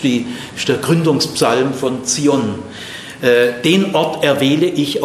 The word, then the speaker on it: Zion: Immer Ton auf der zweiten Silbe, bitte. Gell? Sag bitte nicht Zion. Zion.